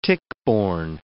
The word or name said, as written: tick-borne